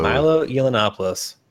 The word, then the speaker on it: Yiannopoulos: Milo Yiannopoulos.